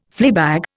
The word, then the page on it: fleabag: Indian